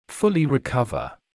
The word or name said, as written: fully recover